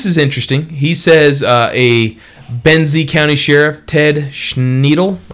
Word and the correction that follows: Schendel: I guess that's isn't how you say his name, Schendel, Ted Schendel, my bad, Sheriff there, says that it's illegal.